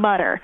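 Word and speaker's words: Mütter: And it is pronounced Mutter, and he did add the umlauts over the U himself. – Right. No one actually knows how he pronounced his name. He was definitely born Tom Mutter. So if you pronounce Mutter, you know, that's not a problem.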